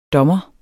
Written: dommer

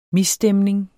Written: MISSTEMNINGERNE

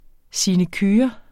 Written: sinecure

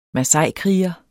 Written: masaikriger

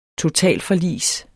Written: totalforlis

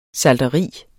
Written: salteri

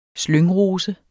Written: slyngrose

